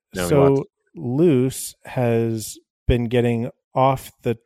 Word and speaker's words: Luz: One is L-U-Z, Loose, in Spanish.